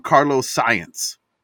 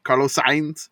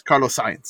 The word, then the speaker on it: Carlos Sainz: I guess I didn't miss much, but he's so mad with, he calls him Carlos Science. – Yeah, I like that. – Like, he can't even say his name right. So, Carlos Sainz. – Love it. It's Carlos Sainz.